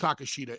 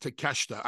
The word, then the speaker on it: Takeshita: And then they, you know, they did the unveiling of the painting, and we find out that, I'm sorry, not Takeshita, it's pronounced Takeshita. I know I always get that wrong, my bad.